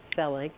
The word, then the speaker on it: Selig: I usually say Selig, but either is fine. – Selig. Okay. No, we want to do it Selig.